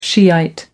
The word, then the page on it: shiite: American English